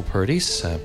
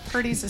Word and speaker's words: Purdies: Yeah, Purdies is spelled weird. – Is weird. Katie Roach, tell us how. – It's spelled P-U-R-D-I-E-S, as though someone's giving you a compliment. Oh, you're awful purdy.